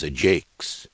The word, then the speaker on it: Ajax: And in Troilus, when Thersites says of Ajax, "For whomsoever he be, he is Ajax," the line sounds a bit pointless until you hear it in original pronunciation. "For whomsoever he be, he is a Jakes."